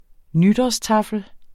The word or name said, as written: nytårstaffel